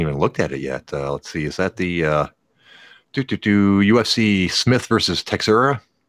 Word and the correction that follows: Teixeira: Well, I'm sure I can't say his name. – Teixeira. – I was closer than I thought I was going to be.